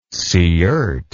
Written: siirt

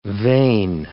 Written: vain

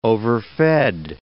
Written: overfed

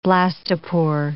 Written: blastopore

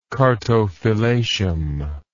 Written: chartophylacium

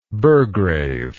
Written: burgrave

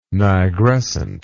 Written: nigrescent

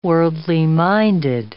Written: worldly-minded

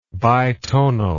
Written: bitonal

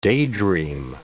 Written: daydream

